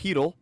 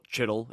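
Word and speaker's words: Chytil: Philip Hedl. That's how it's pronounced. It's spelled Chittle, if you're one of the people who say it that way. It's Hedl.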